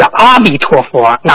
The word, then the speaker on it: 阿弥陀佛: 啊，下一个问题，师傅，那个阿弥陀佛是阿弥陀佛，哪个读音更为准确一些？ – 其实阿弥陀佛和阿弥陀佛都可以。